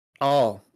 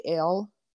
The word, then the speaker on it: El: Usually in modern they say El, as in the beginning of Elohayam or Elohim. It should be pronounced all, but pronouncing it El is how they do it in modern.